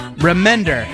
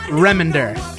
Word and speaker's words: Remender: It's Remender, not Remender.